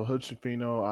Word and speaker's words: Hood-Schifino: Okay, so Hood-Schifino out of, I actually pronounced that right.